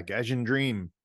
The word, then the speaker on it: Gaijin Dream: I have, and I'm not exactly sure how to say it, Gaijin Dream. – Yeah, Gaijin Dream.